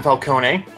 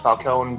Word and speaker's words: Falcone: It's directed by her husband, Ben Falcone. I'm sure his last name is actually pronounced Falcone, but saying it that way makes him sound like a Batman villain, so I'm sticking with it.